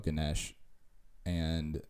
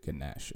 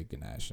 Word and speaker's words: Ganesh: and so you pronounce it Ganesh. At least all the Indian people I've met, they say Ganesh, and like, or Ganesha, depending on the region of India you're from.